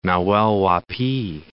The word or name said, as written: Nahuel Huapi